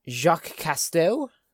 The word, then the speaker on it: Jacques Cousteau: But believers point to a quotation from undersea explorer Jacques Cousteau, I believe I pronounced that correct. – You have.